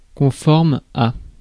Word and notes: fitting: /fɪ.tɪŋ/; American English; [fɪ.tɪŋ]

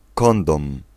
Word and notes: capote: [yn ka.pɔt]; France (Île-de-France)

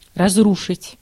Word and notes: разрушить: /rɐzˈruʂɨtʲ/

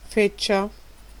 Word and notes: trash: American English; [tɹæʃ]

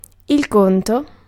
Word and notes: check: American English; [tʃɛk]

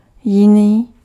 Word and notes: jiný: /jɪniː/

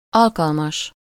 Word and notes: convenable: France; /kɔ̃v.nabl/

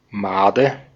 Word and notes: Made: /ˈmaːdə/

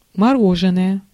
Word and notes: мороженое: /mɐ.ˈro.ʐɨ.nə.jə/; moroženoje

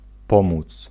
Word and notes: aiutare: [a.ju.ˈta.re]

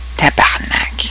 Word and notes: tabarnak: Québec; [ta.baʁ.nak]; /ta.baʁ.nak/

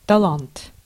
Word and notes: талант: /tɐˈlant/; talant

